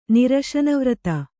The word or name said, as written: ನಿರಶನವ್ರತ